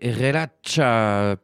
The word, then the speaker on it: Relaxa: Vous êtes toujours sur Divergence FM 93.9 et c'est Andrea Dacal qui nous interprétait Relacha, puisque le X se prononce tch en brésilien.